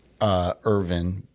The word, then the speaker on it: Irvine: So the locals call it Irvine. The correct pronunciation is Irvin.